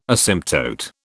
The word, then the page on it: asymptote: British English